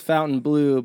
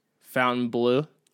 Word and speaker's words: Fontainebleau: And the park is Fountain Blue, but it's spelt Fontainebleau.